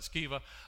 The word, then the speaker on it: Sceva: Seven sons of Sceva, or some pronounce that Sceva, a leading priest, were doing this.